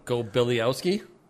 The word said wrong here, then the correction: Golibiowski: Go Billy Owski. How do you pronounce it? – Ed Golibiowski. – Golibiowski. – Oh, thank you.